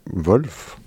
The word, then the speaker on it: Wolf: Wolf, comme ça, c'est comme ça qu'on prononce son prénom. – Je pense qu'on dit Wolf.